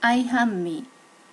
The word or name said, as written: aihanmi